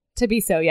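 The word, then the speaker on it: Thabiso: So with a TH? – Yeah, but I wrote it out TAH, Tabiso, and she was like, that's right.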